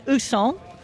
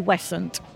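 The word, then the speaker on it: Ouessant: Some people say Ousson. Some people say Wisson, but we tend to say Wessant.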